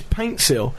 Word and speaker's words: Paintsil: I loved John Pansil, quite the opposite of that. – Or Panstil, as his shirt says. – I keep getting his name wrong. Yeah. Pansil's not even his name. His name is Paintsil, but it's always been spelt wrong by the Ghanaian FA, apparently.